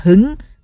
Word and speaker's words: hứng: Đào Căng Tiên nói: vậy ba chữ Hưng Chi Thần nghĩa là gì? Đào Diệp Tiên nói: chữ Hưng ở đây đọc là hứng, hứng là cao hứng, hứng chi thần nghĩa là tinh thần rất cao hứng.